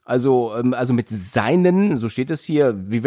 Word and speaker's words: Seinen: Soweit ich weiß, spricht man das Seinen aus. – Ich habe auch gesagt Seinen.